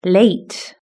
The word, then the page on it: late: British English